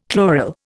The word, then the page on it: chloral: Indian